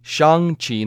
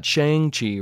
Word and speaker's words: Shang-Chi: And notice how I pronounced it correctly Shang-Chi, not Shang-Chi, right? It rhymes with Hung and not Wang. So that's boom. That's how you remember it, okay? Hung, not Wang, even though that's kind of confusing a little bit too, since they both, you know, have something in common.